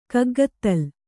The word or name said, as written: ಕಗ್ಗತ್ತಲ್